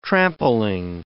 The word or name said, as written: trampling